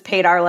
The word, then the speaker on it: Paydar: I don't, but it's easy because it's Paydar like radar.